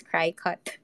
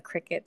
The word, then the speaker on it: Cricut: I thought it was originally pronounced as Cry Cut, but apparently not. I watched some videos, and they called it a Cricut. So like, oh okay, like kind of like the bug.